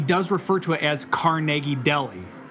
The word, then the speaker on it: Carnegie: Honestly, I know the the right pronunciation is Carnegie.